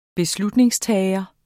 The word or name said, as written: beslutningstager